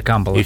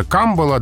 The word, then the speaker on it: камбала: Но эфирное – это камбала. – Эфирное – это камбала, да, это образцовое произношение, демонстрирующее эталонное владение нормой.